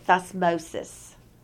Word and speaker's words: Thutmose: Thutmose, if you want to spell it, the way they spell it is Tutmose, the third. But they pronounce it Thutmose.